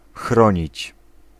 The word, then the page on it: beschermen: /bəˈsxɛrmə(n)/